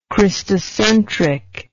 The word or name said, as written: Christocentrism